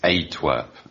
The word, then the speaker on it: Antwerp: Yeah, sorry, I think you mispronounced that. It's Antwerp, isn't it? – Close, yeah.